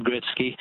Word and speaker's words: Gretzky: Wayne Gretzky has always been pronounced Wayne Gretzky, and I suspect Michael just wasn't familiar with how to pronounce it when he read it off off.